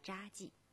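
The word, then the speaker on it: 查济: 镇，可能朋友们会想到黄山脚下的西递宏村，但是今天我们去的却是扎记古村。在黄山山脉北部的群山之中，深藏着一个令人称奇的村落，它的名字叫做扎记。扎其实就是茶字典的茶字，但是在这里呢，多音字要念成扎，因为这里的大部分人都姓扎。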